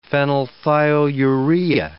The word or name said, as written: phenylthiourea